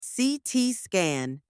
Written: CT scan

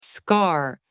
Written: scar

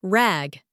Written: rag

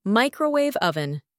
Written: microwave oven

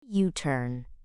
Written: U-turn

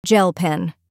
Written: gel pen

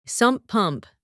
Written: sump pump

